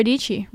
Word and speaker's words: Dici: And honestly, it's just such an honor to be a part of it as Sarah Dici, rhymes with Peachy. So let's get into it. Welcome to That Creative Life. Hi, my name is Sarah Dici and I am your host.